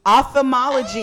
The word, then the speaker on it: ophthalmology: Ophthalmology? – Ophthalmology.